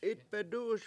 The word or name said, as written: 7itbedU7uS